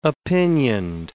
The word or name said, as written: opinioned